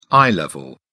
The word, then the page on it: eye level: British English